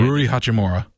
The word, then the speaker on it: Rui Hachimura: And 11, Ryu Hachimura. – Butchered that one. Yeah. Rui Hachimura.